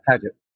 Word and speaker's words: Paget: Yeah, Ed Paget.